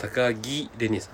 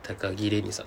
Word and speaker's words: 高城れに: 気をつけてください、はい、高木レニさんですそうはい、はい、城と書いて高木レニさんです、